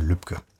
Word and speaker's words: Lübcke: Das ist nicht Herr Lübecker, sondern Herr Lübcke.